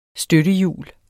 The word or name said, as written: støttehjul